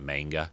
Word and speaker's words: manga: And I picked up the first volume, I'll call it, of the manga series based on, that's how you say it, manga, right? Not manga, manga, which is Japanese, basically, comic book, or Japanese comic books.